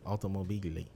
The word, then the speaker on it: Automobili: That's how you say it in Italian, right? Automobili. – Oh, that's never mind. You did say it right.